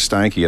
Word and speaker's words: Steinke: I thought, you know, they're not going to like Steinke at all. It'll be Stinky or Stanky or something. They won't pronounce it right.